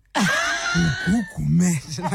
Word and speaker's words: Gugu Gumede: I'm sorry, I'm laughing because I like how you pronounce my name. Gugu Gumede, like, girl.